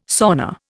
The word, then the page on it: sauna: Indian